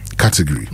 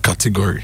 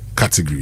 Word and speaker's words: category: Not category. – Dabby, dabby, dabby, dabby, dabby. – The gory, no. If you are describing a gory accident, Charlie. So if you want to say it right, category.